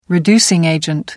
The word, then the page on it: reducing agent: British English